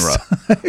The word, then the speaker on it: UNRWA: The W is silent in yes. – UNRWA.